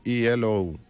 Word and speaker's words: Elo: Πέραν δηλαδή από τα στατιστικά, υπολογίζει τη σημερινή αγωνιστική δυναμικότητα κάθε ομάδας, μεταξύ άλλων τα αποτελέσματα και τη βαθμολογία ILO. ILO ή ELO, δεν ξέρω πώς προφέρεται. Είναι ELO.